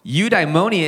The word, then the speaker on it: eudaimonia: The first one is this eudaimonia, right? It's one word, but I just said it in its syllables. Eudaimonia.